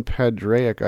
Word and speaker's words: Padraic: Uh, you can follow me on Twitter at Colin Padraic. I don't think that's how you pronounce it, I'm told. It's my middle name, but I don't know how you say it.